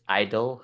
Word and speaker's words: I-dle: Whoa. It's just Idol. – Okay, Idol's your favorite. – G is silent. I don't know how many times I say this.